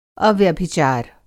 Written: अव्यभिचार

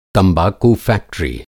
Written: तंबक़ू-फ़ैक्टरी

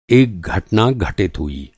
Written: घटित

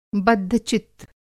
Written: बद्धचित्त

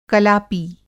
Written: कलापी

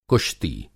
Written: कुश्ती

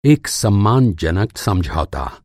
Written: सम्मानजनक

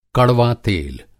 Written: कड़वातेल

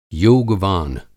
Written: योगवान

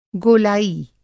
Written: गोलाई

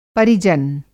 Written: पर

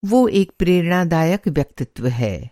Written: प्रेरणादायक

